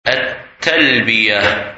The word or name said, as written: Talbiyah